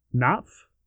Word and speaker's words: Knopf: Knopf. – I know somebody has a K in the front of their name, and it's pronounced Knopf.